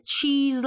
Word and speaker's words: cheese: cheese 咧，cheese 你知道 e s e 吓，嗰个 s 咧其实系发 z 嗰个音嘅吓，咁、啊、但系咧对于我哋嚟讲咧，广东话人咧全部都系屎，就系巴士、的士、多士、芝士啦，系咪？